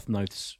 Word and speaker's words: Noth: If we've learned one thing this week, it's how to pronounce Christopher Noth's name. – Okay, so it's Chris Noth rhymes with both.